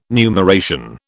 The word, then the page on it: numeration: British English